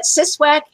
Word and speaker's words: CSWAC: how do you say the short version? – We usually call it CSWAC.